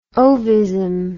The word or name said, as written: ovism